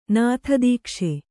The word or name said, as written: ನಾಥದೀಕ್ಷೆ